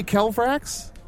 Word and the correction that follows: Kelvaxis: Am I saying that right? – Oh, I think it's Kelvaxis.